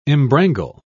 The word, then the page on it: embrangle: British English